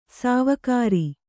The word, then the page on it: ಸಾವುಕಾರಿ: sāvukāri; kannada